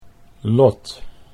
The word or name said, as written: Lot